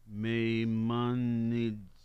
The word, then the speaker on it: Maimonides: In 1490, the first dated edition of Maimonides.